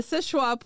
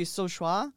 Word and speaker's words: Seul Choix: It is called the Sishua Point Lighthouse. It is spelled S-E-U-L-C-H-O-I-X. – Yeah. In modern French, it would be Sochois. – Yeah. – In their French, it would have been Sochois. – Yeah. – Neither of those sound like Sichois.